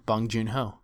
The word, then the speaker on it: Bong Joon-ho: A Korean film from Bong Joon-ho. I'm horrible at pronouncing things like that. Was I correct when I said that? – Yeah, Bong Joon-ho.